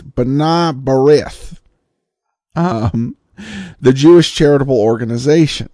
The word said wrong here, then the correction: B'nai B'rith: The pronunciation, as I heard it on New York airwaves growing up, was B'nai B'rith. B'nai B'rith.